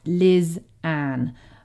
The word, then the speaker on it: Lysanne: So my name is Lizanne, as I said before. It's spelt L-Y-S-A-N-N-E and it's pronounced Lizanne. A lot of people get the pronunciation wrong because of how it's spelled, and I totally get that.